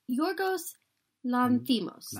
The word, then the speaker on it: Yorgos Lanthimos: Yes, it's Yorgos Lanthimos.